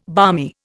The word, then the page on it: balmy: Indian